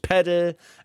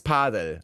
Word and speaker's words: Padel: Padel spielen. So, nicht Padel, nicht Paddel. Es ist Padel.